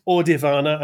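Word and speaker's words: Audirvana: And It's pronounced Audivana, and I have a bit of a bug up my ass about this because I hear so many people pronounce it Audiovana or Audio Nirvana or whatever.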